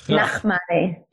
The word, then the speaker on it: Nachmany: Yeah, you could add a little bit of huh. Nachmany, but otherwise that's perfect.